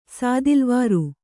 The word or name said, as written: ಸಾದಿಲ್ವಾರು